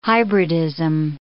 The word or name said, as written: hybridism